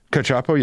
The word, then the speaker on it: Cacioppo: But how do you pronounce his name again? Is it the loneliness expert, John Cacioppo? – Cacioppo, yeah.